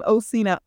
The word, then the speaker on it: Occena: So yeah, if you're listening and you are wanting to check out Sean Osina Music, my name is spelled differently. Let me spell it for you. It's C-H-A-N is Sean, last name Osina, O-C-C-E-N-A, and then music.